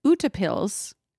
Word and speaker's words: utepils: It is Norwegian, and you pronounce it utepils. Utepils. And it literally translates to outdoors lager.